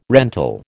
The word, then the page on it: rental: American English